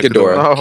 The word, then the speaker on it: Ghidorah: I don't know if I'm saying Ghidorah. Yeah, Ghidorah.